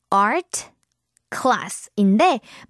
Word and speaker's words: art class: 자, 여러분 이거 원래 art class인데 빨리 하면 그 art 할 때 그 t 사운드가 거의 발음되지 않죠? Art class. Art class 이렇게 발음하면 되고요.